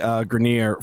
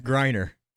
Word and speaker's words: Griner: And that's Dennis Rodman going to Russia to get out Brittany Griner from Russia. Did I pronounce the name right? – No? – Griner.